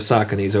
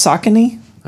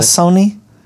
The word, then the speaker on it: Saucony: That's pronounced Saucony? – That's how I've always said it. – Not Sassoni? – It's Saucony. – Is it really? – Yeah, I'm pretty sure it's Saucony.